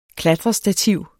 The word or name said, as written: KLATRESTATIV